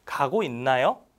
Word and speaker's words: 가고 있나요: 가고 있나요? Right? And some people, somehow, they pronounce it like 여, not 요.